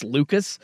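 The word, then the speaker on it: Lukes: His name looks like Luke's, but it's pronounced Lucas.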